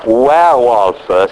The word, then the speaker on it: werewolf: Però werewolf si pronuncia esattamente dove è il lupo, where is the wolf.